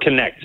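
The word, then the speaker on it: Knecht: And then Robert, how do you pronounce his last name? – Robert Connect. Like Dalton Connect.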